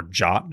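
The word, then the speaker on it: JWT: So the token itself that it wants to verify, the JSON Web Token, or JOT, as we have discovered it is now pronounced, a secret or public key.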